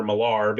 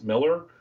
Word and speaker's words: Millar: Everyone wants to call Miles Miller Millar because it's spelled M-I-L-L-A-R, but it's pronounced Miller.